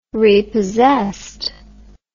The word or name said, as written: repossessed